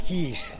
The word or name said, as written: gixh